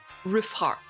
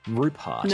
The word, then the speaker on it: Ruphart: Yeah, I pronounce it like Rufart because that's kind of how Penn says it, like as in roof on a house and heart as in the organ. – Yeah, so you couldn't see that in the book, the name is spelled R-U-P-H-A-R-T, Rupart.